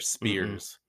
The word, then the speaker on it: spheres: S-P-H-E-R-E-S. – Yeah. Say it like S-F, not spheres.